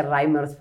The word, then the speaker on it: Reimers: se quedó así pero bien lo dice, se pronuncia Reimers, pero bueno, es un detalle en el que no podemos insistir.